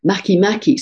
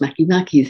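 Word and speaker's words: Makemake: Maki Maki, spelt make make but pronounced Maki Maki, the link to the great sea spirit of Easter Island.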